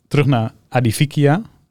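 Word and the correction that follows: Aedifica: Zeg ik het goed? – Adifica. Adif, ah, Edifica? Edifica.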